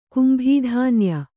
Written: ಕುಂಭೀಧಾನ್ಯ

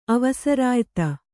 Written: ಅವಸರಾಯ್ತಿ

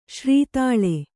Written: ಶ್ರೀತಾಳ

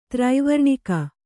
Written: ತ್ರೈವರ್ಣಿಕ

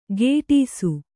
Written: ಕೆಡಿಸು

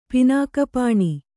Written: ಪಿನಾಕಪಾಣಿ

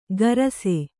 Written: ಕಾರಸೆ

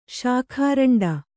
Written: ಶಾಖಾರಂಡ

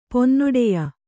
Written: ಪೊನ್ನೊಡೆಯ

